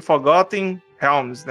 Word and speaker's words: Forgotten Realms: A narrativa aí de Forgotten Realms, né? Reinos Esquecidos. Boa pronúncia, boa pronúncia.